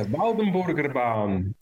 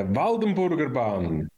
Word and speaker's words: Waldenburgerbahn: Now, I said it was called the Waldenbergen ban. I have no idea if I've pronounced that even appropriately correctly. Would you like to give us the non-Scottish accented? It's the Waldenburgerbahn. But say that again. The Waldenburgerbahn. Yeah, yeah, I'm not going to be able to say it like that.